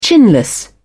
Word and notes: chinless: British English

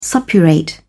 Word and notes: suppurate: British English

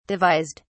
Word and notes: devised: British English

